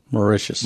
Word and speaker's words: Mauritius: ambassador, and I should have asked how to pronounce this, U.S. ambassador to the Republic of Mauritius? – Mauritius.